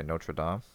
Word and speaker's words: Notre Dame: Because I say Notre Dame.